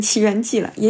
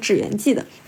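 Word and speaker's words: 祇園祭: 那最有名的肯定还是京都七月份的奇缘祭，我一般读成奇缘祭了，也有读成纸园祭的。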